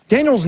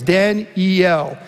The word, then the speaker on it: Daniel: Daniel's name is very significant. It's a three-syllable word. You may say Daniel, but it's actually three syllables, Dan-E-L.